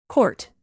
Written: court

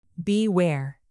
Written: beware

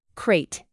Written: crate

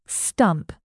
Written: stump

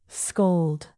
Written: scold

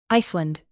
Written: iceland

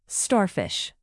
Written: starfish

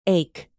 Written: ache

